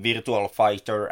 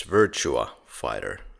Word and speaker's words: Virtua Fighter: And Eric, I guess more than Shenmue, Eric is known for his voice acting in Virtual Fighter and Tekken series. – Good God, you should repronounce that. It's Virtua Fighter, no L there.